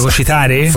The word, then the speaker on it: fagocitare: E poi hai gli anticorpi per fare mercato, ti fai un po' fagocizzare dalle situazioni. – Fagocizzare? – Non si dice fagocizzare. – Fagocitare? – Quello lì è bello fagocizzare, sì.